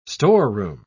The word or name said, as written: storeroom